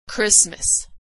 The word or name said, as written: Christmas